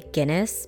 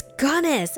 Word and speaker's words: Gunness: And I would always just mispronounce it and say Guinness because I think he's part Irish too. And he would just be like, no, it's Gunness. I'm like, yeah, that's what I said, Guinness.